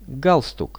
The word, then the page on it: galstuk: Russian